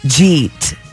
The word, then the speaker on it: jeet: In Oklahoma, jeet. – Jeet. – How you spell it? – J-I-I-T. – No, J-apostrophe-E-E-T. – Jeet? – Oh, did you eat? – Yes, yeah.